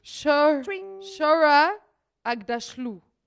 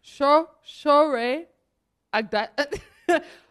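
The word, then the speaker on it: Aghdashloo: Shora Agdashlu. No, that's not how you pronounce her name. Agdashlu. Shore Agdashlu.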